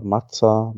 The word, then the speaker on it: Maza: Und die, die, die Marke heißt M-A-Z-A, Mazza, Mazza.